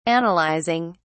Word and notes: analyzing: British English

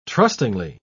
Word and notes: trustingly: British English

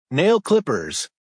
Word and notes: nail clippers: American English

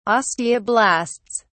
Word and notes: osteoblasts: British English

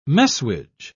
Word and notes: messuage: British English

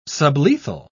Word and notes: sublethal: British English

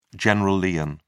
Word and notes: general lien: British English

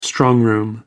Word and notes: strongroom: American English

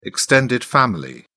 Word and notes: extended family: British English

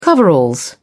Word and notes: coveralls: British English

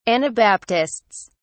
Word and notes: anabaptists: British English

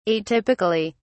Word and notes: atypically: British English

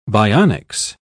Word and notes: bionics: British English

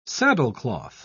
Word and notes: saddlecloth: British English

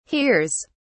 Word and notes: hears: British English

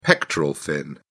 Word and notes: pectoral fin: British English

